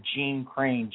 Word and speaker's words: Gene Crain: Or you can find me under Gene Crane, G-E-N-E-C-R-A-I-N, on Facebook. – Spell it again. – G-E-N-E-C-R-A-I-N.